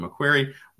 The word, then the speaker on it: Macquarie: Analysts at Macquarie, I believe I spelled that.